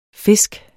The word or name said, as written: fisk